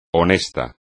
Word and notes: honesto: espagnol

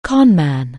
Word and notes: conman: anglais

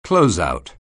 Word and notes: closeout: anglais

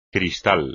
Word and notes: cristal: espagnol

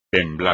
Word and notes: temblar: espagnol

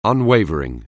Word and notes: unwavering: anglais

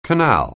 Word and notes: canal: anglais

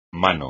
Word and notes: mano: Spanish

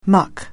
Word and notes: muck: anglais

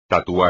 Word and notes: tatuar: espagnol

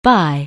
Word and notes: bi-: anglais

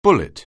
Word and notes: bullet: anglais

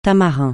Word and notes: tamarin: francais

